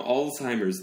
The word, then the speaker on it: Alzheimer's: 그냥 Alzheimer's disease. 그래서 아. 항상 그 S 붙여서 말합니다. – 그러니까 어에 가깝네. R이 아니고. – 네, Alzheimer's. Alzheimer's disease이라고 하고요.